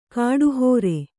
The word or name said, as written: ಕಡೆಹರಿ